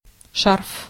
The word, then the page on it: écharpe: France; [e.ʃaʁp]